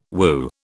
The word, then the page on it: whew: British English